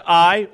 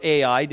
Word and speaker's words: Ai: Joshua sent men from Jericho to Ai, or Ai, depends on how you pronounced it growing up, which is near Bethaven, east of Bethel, and said to them, "Go up and spy out the land."